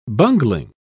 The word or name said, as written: bungling